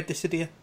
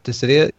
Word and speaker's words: Dissidia: Am I pronouncing it right? Dissidia? – Yeah, Dissidia. Yep. I think so. – Dissidia, yeah. Yeah, that's good.